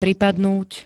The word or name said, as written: pripadnúť